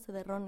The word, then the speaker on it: cederrón: Eh, cederrón, la palabra cederrón existe en la Real Academia, se escribe así como suena, cederrón, ¿no? Con acento y en el final,